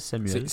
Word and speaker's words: Samuel: Curtis Samuel. C'est comme ça qu'il prononce d'ailleurs. Il dit Curtis Samuel.